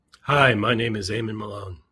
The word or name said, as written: aemon